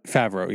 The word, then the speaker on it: Favreau: Favreau, yeah.